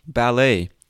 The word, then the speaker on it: ballet: Ballet. B-A-L-L-E-T. The T is silent. Ballet. Bouquet. B-O-U-Q-U-E-T. Bouquet. Or boutique. B-O-U-T-I-Q-U-E. Silhouette. S-I-L-H-O-U-E-T-T-E.